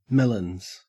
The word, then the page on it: melons: Australian English